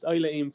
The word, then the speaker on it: Isla Eam: The second hard horse to pronounce, I think it's pronounced Isla Eam, it's running today. It's Isla Eam for Simon and Ed Crisford.